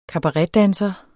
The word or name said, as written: kabaretdanser